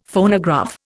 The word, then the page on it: Phonograph: Indian